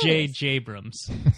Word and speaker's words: J.J. Abrams: He is the one that was like, it's pronounced J.J. Abrams.